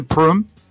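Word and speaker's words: Prüm: Or today, we have Raimund Prûm.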